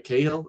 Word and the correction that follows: Cargill: is that how you say it? – Jade Cargill, yeah.